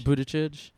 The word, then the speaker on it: Buttigieg: So I feel like Warren or, how do you say it? Buttigieg? – Buttigieg.